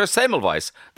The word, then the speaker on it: Semmelweis: And the legendary Mark Rylance speaks to us about his new play called Dr. Semmelweis.